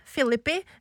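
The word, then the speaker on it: Phillippe: Ja, og det var jo da Jennifer Love Hewitt, Sarah Michelle Geller, Ryan Philippi, som jeg lærte at man uttaler det, Philippi, uh, eh, Freddy Prince jr.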